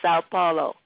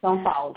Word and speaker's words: São Paulo: São Paulo. Woo! – São Paulo, yeah.